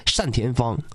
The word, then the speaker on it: 单田芳: 我记得原来那个原来我有个牌子我挺喜欢的，那个字是多音字，放在人的姓上应该叫单田芳。